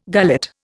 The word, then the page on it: gullet: Indian